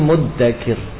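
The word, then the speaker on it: muddakir (مُدَّكِر): Dan Ibnu Mas'ud mengatakan, aku mendengar Rasulullah membacanya fahal mim muddakir, ya, dengan dal tanpa titik dal, bukan za, bukan fahal mim muddakir, tapi fahal mim muddakir.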